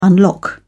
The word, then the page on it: unlock: British English